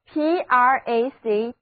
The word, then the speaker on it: practicable: 所以 PRACTICABLE 就读作 practicable practicable，意思就是能够实行的，行得通的。字母 A 读梅花音，而两个字母 C 都发清辅音 K。